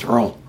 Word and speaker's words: Rouen: We went to Rouen, which everybody in France pronounced Rouen.